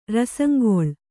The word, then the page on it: ರಸಂಗೊಳ್: Kannada